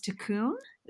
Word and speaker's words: tikkun: And the Kabbalah term, and I'm probably going to mispronounce this, tikkun. Is that how you say it? – You got it right.